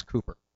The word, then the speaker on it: Cooper: My name is Cooper. It's easy enough, C-O-O-P-E-R.